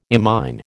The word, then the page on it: imine: American English